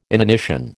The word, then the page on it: inanition: American English